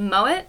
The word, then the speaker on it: Mowat: His name is Donald Mowat. You don't pronounce the T, so sorry if I am.